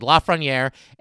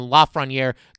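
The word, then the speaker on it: Lafrenière: He said Lafreniere and asked Alexi if that was good. And Lafreniere confirmed that that indeed is the correct pronunciation. So I think we're good calling him Lafreniere going forward.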